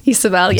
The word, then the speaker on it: Jezebel: Jezebel, yes.